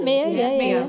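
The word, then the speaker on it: Meo: It's M-E-O. Am I saying that correctly? – Yeah, Mio. – Like Tony Mio.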